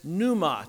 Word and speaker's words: pneuma: The word for spirit is pneuma. It's got the silent P at the beginning.